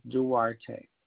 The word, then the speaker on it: Duarte: And the last name is Duarte.